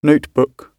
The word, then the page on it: notebook: British English